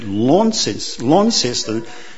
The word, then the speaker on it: Launceston: We were in Launceston. I've been taught how to say that properly. Launceston for 13 nights.